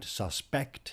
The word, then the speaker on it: suspect: And suspect here, as indeed defect above, are both stressed on the second syllable, not, as we would do if they were adjectives, on the first.